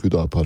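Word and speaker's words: HÜDA PAR: Kısaltma olarak Hüdapar şeklinde telaffuz ediliyor.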